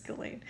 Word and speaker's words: Ghislaine: And then I started thinking, well, Ghislaine Maxwell, or how do you say it? Ghislaine? Ghislaine? Oh, it's Ghislaine, sorry. It's Ghislaine.